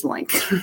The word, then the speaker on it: LOINC: LOINC is an acronym and pronounced as LOINC.